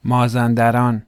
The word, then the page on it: مازندران: Persian